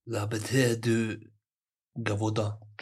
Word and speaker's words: Gévaudan: Le Bête de Gévaudan.